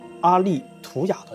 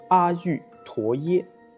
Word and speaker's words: 阿育陀耶: 那再后来呢，阿北找到了一个在加拿大留学的亲友，他班上有很多东南亚人，阿北就跟他讲这个梦，让他帮问问这个叫做阿利图雅的这个说法啊，就这个发音的说法。他问了之后啊，这个就是这个亲友的泰国同学纠正了他的发音，他告诉阿北说应该发音是叫做阿育陀耶啊。